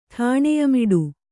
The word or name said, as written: ಠಾಣೆಯಮಿಡು